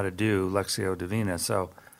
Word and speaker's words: Lectio: Lectio is L-E-C, believe it or not, L-E-C-T-I-O. It's pronounced Lectio for some reason. Lectio Divina, D-I-V-I-N-A, means divine reading in Latin.